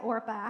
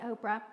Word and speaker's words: Orpah: All right, I want to look real quickly at Orpah. I hope I'm saying her name right, but I always want to say Oprah.